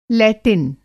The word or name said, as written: लैटिन